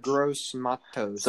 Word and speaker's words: Gross-Matos: Yedder Gross-Matos.